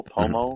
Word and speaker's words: Pomo: So, it's P-O-M-O, Pomo. – Mm-hmm. Pomo, okay.